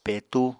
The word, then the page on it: petú: Apatani